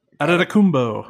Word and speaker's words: Adetokounmpo: And with the 60th pick, we selected Kostas Adetokounmpo.